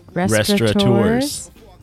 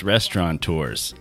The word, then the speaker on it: restaurateurs: Restaurateurs? – You might think it's restaurateurs.